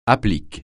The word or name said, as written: applique